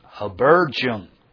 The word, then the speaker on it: Habergeon: That word there, Habergeon. I'm not sure how that would be pronounced in Canada, but that's how I pronounce it.